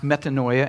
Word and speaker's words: metanoia: The word repent in the Greek is pronounced metanoia, and it means to change.